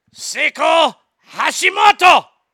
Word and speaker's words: Seiko Hashimoto: Seiko Hashimoto! That's how they say it there.